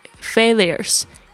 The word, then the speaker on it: failures: Remind you of past failures. F-A-I-L. I-L 是 fail, 就是失败的意思。它的名词后面加 U-R-E-S, 特别注意一下它的发音 failures, 那个 U 是发 A-A-A 的声音，不是 U 的声音 failures. Breakups, 这个是分手 B-R-E-A-K-U-P, 不止一次分手就加上 S, breakups.